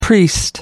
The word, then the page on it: priest: American English